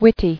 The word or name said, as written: Wittiness